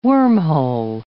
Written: wormhole

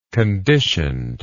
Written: conditioned

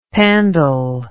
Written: pandal

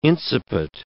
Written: incipit

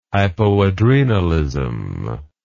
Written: hypoadrenalism

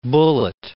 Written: Bullitt

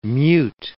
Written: mute

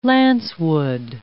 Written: lancewood

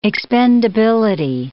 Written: expendability

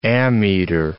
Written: ammeter

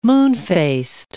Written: moonfaced

